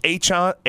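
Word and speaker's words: Achane: We had a 200-yard rusher this week, and Devin, it's Achan. I know there's been some debate about how you pronounce his last name, but Devin Achan looked unbelievable.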